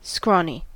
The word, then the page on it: scrawny: American English